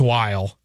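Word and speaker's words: Wile: I think it's Willie or Weil, but I haven't asked. – It's W-I-L-E, right? – Yeah. – I've heard it's Weil.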